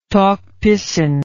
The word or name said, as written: Tok Pisin